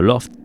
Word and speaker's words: L0pht: Este segundo libro se centra en las intrusiones en las redes informáticas realizadas por desconocidos o grupos de hackers de renombre, como Loft, escrito, o sea, L0PHT, pronunciado Loft, pero escrito en lenguaje LIT, que fueron un conocido grupo de hackers con sede en Boston y fundadores de Loft Heavy Industries.